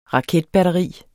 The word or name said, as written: raketbatteri